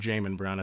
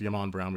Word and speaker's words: Jamon: You had an interview with Yamon Brown, and for everyone out there, I kept calling him Jamon Brown. I think it's pronounced Yamon Brown.